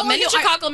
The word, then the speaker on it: meningococcal: Meningococcal meningitis.